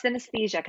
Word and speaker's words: synesthesia: Interprets people's places and experiences through color, a condition known as synesthesia. I don't know if I said that right. – Oh, you're close. It's synesthesia, kind of like anesthesia.